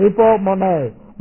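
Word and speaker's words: hupomone (ὑπομονή): I put it on the board, but I'm going to stir you into some kind of spiritual ecstasy, but it is hupomone, long E, hupomone.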